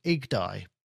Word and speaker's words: YGDAI: I think it's called IGDAI. It's how you say it. But I'm going to say YGDAI.